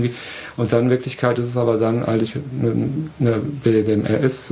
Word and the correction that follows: BDSM: ich kann es nicht mal aussprechen, ups. – BDSM. – BDSM, genau.